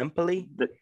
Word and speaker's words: Empoli: Juve dropped 1-0 to Empoli. Is that how you pronounce yeah. – it? – Yeah. – Empoli. – You said it right.